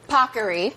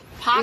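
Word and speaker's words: Paccheri: This is, as the Today Show team made sure I pronounced properly, Pockery. – Pockery.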